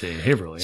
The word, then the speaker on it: Haverhill: Haverhill. It's spelled Haverhill, but in Massachusetts, nothing spelled or sounds the way it's spelled. – It's Haverhill.